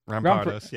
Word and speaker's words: Rampardos: Rampardos, yeah.